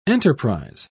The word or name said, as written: enterprise